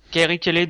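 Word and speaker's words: Carrie Kelley: Donc c'est Kerry Kelly, je sais pas si je le prononce bien. Oui, Kerry Kelly, oui.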